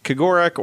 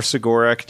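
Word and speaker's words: Cegorach: They are followers of the one remaining living Eldar god, which is Kagorak or Sigorak, depending on how you want to pronounce it, whether you decide that first C is soft or not.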